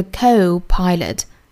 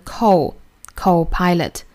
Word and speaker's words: co-pilot: 后面的这个 co-pilot， co 是英音的发法，美音的发法呢是 co-pilot， co-pilot。